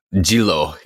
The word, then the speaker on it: Jinro: I would say Jinro, but in Korean, they pronounce that. – Jilo, yeah. – Jilo, yeah. So anyway, it's Jilo Fresh, which is their neutral version.